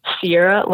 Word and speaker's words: Siera: My first name is Siera, like the truck, but minus an R. So S-I-E-R-A. And last name, Bearchell, B-E-A-R-C-H-E-L-L.